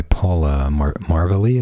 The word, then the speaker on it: Paula Marvelli: I know in this book written by Paula Marvelli, is that how you say it? – Yes, that's her name, yes.